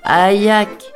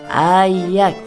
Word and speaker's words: aïak: Il disait un aïak en appuyant bonne sur les deux syllabes. Ayak » C'est quand même plus fort que si on dit burk ou ben c'est dégoûtant.